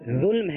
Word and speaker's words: ظلم: یہ ظلم ہے ساکن،